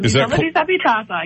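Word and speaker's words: L'isola disabitata: Help me out if I get it wrong. Lisola Disabitata. – That's very good. Lisola Disabitata, yes.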